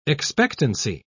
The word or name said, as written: expectancy